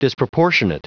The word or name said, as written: disproportionate